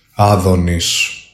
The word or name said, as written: ADONIS